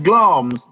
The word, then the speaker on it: Glamis: It's going back to Glamis, obviously, it's pronounced Glamis, Glamis, Ron.